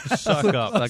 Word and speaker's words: Succop: Zoc, as you would know, Ryan, S-U-C-C-O-P is pronounced suck up.